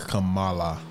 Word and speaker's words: Kamala: Is it Kamala or Kamala? – I'm assuming because she's Indian, it's Kamala.